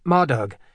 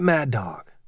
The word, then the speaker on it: Madhug: Even though Nick knew the name was pronounced Madhug, he, like most of the people in his class, usually pronounced it Mad Dog.